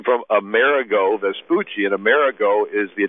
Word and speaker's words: Amerigo: And the Italian pronunciation of Emmerich is Amerigo or Amerigo,